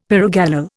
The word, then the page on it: pyrogallol: Indian